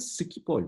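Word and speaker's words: Schiphol: Skipol değil, Skipol mi, Skipol mi? Galiba Skipol. Skipol. Hollandalılar öyle telaffuz ediyorlar. Onlar şey, demiyor ona. Onların dilinde daha böyle bir K'li sanki o.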